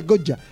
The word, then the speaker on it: Goya: Soy de Goya. Pronunciado así, esa Y pronunciada a lo correntino.